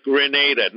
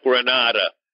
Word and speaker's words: Grenada: Pronounce Grenada, not Granada. Granada is a place near Spain, near the Gibraltar area.